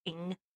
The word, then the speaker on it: Ng: I know how to say her last name. It's just NG, but it's fantastic because her Instagram handle is it's pronounced Ing.